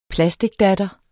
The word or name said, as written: plasticdatter